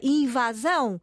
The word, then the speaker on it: invasão: E já em latim o A era aberto, portanto o A é aberto, sem dúvida, apesar de se tratar de uma palavra aguda. O que é que é uma palavra aguda? O seu acento tónico está na última sílaba, invasão. Mas há muito, há tantas outras palavras que têm a tónica noutra sílaba e o A é aberto.